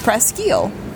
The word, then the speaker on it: Presque Isle: But the Presque Isle I am used to is near Alpena, Michigan, and it's pronounced Presque Isle. – Yeah, because it's French.